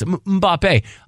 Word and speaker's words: Mbappé: And now it's like, with the way the accent is on the end of his name, you gotta French it up a little bit. Mbappe.